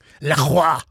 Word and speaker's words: LaCroix: I bet he'd say something like LaCroix or some bullshit. – How do you say it? – LaCroix.